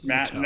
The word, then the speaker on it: Mathieu: Mateau? – Tyrann Mathieu is who it was. – Matthew. – That's how you say his name.